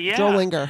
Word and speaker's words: Drollinger: Drollinger.